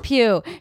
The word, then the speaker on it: Pugh: Florence Pugh? – Pugh.